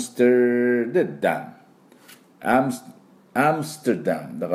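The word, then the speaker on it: Amsterdam: ですから 3 音でアムステルダムと発音して、これはおみゃーの音がポイントです。あとアクセントね、最初アムステルダム、ここにももう一個、第二アクセントがありますので、ね、同じこのおみゃーのポイントです。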